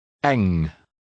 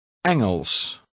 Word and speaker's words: Engels: The NG combination is pronounced as in the English word long. – Eng. – 17. – Engels.